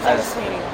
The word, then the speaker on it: Sweeney: Sarah Sweeney. – Yeah. – That's how you pronounce it. – Uh-huh. That's... Yeah. Spelled like it sounds.